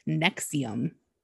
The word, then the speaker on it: NXIVM: It's spelled very weird. Nexium is actually a prescription drug. This is actually Nexium the cult. And it's spelled N-X-I-V-M.